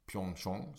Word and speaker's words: Pyeongchang: I det som heter Sweden Arena, som är ett sådant Sverigehus som de har i Pyeongchang, som jag lärt mig att det ska uttalas.